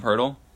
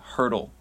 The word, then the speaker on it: Poeltl: Jakob Poeltl. No, yeah, it's spelled P-O-E-T-L. – I thought it was Jacob. – It's Jakob Poeltl, like H, oh. Like hurdle. All right, but it's a P.